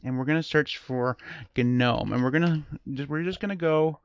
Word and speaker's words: GNOME: It is pronounced GNOME for a reason, because GNOME is an acronym.